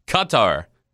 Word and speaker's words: Qatar: But so Grant, you just nailed it. The third one that you said was the correct one. Qatar. That's kind of how they say it.